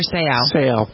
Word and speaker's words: Seau: Junior Seau.